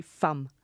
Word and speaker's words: Femme: Generell geht es immer um diese verführerische Weiblichkeit, diese Femme Fatale, würde man vielleicht... – Femme. Die Aussprache ist literally, egal was der wer sagt, es ist literally Femme.